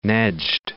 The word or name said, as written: Nejd